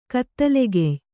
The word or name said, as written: ಕತ್ತಲೆಗೆಯ್